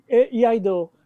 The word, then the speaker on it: Iaido: You mentioned that you were an expert in martial arts, in the Japanese martial art of Lado. Am I saying it right? – Iaido.